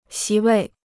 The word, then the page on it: 席位: xí wèi